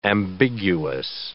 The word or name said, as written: ambiguous